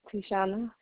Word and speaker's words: Teishauna: Okay, how do you spell it? – T-E-I-S-H-A-U-N-A. – Okay, you said Tishana. T-I-S-H-S-U-N-A. Huh? – No. T-E-I. – Oh, T-E-I. All right, hold on. Let me get back. T-E-I. – S-H-A. – Uh-huh. – U-N-A. – S-H-A. Okay. S-H-A-U-N-A.